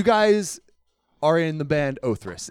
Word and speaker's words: Othrys: We've been called Others. We've been called Othritis. We have been called all sorts of different things.